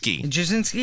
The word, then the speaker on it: Brzezinski: How do you say her last name? It's Mika Brzezinski? – Brzezinski. – Okay. – At least that's how Rush used to say it.